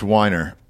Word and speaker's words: Weiner: If they don't like the way it sounds, so like the creator of Mad Men, Matthew Weiner, and when he's like, Weiner. No, it's Weiner. And I'm like, no, the fuck it isn't. – It's Weiner, bro.